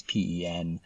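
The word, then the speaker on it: Pen-y-Crug: So it's a shot out early morning from horrible Welsh pronunciation, even though I'm from Wales, my Welsh pronunciation isn't great, but it's the Penygrig hill fort. It's not spelt like that at all, but if anyone wants to look it up, it's Penygy-Crug hill fort,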